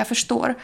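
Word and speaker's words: förstår: Например, вот в этом первом случае, я фештор, вы слышите, что это слово произносится с ш, звуком ш, но на самом деле пишется оно через рс. – Фештор, ферстор. Дело в том, что в шведском эти буквы дают звук «ш», когда они стоят вместе.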